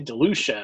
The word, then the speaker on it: DeLucia: We have Richard Rich Anthony DeLucci. Did I say that right? – DeLucci. I would say DeLucia, I guess.